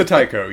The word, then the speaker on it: Taiko: Taiko, right? Taiko? – The Taiko appointed these Taiko five guys. Taiko died. I'm trying to... No, I'm struggling with... Still struggling with pronunciation. It's not the issue. – He's the Taiko, yes.